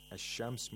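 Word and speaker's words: ash-shams (الشمس): Ash-shams means the sun. So what happens is that the lam is not pronounced whenever a sun letter comes after alif lam.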